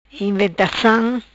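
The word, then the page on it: Inventacam: Patuá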